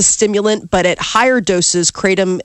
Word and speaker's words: kratom: The Drug Enforcement Administration, the DEA, this week is set to make kratom a Schedule 1 drug. That low doses, kratom acts as a stimulant. – How do you spell it? – K-R-A-T-O-M.